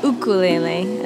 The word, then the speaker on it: ukulele: I discovered the ukulele, ukulele, as it's properly pronounced, about 2.5 years ago.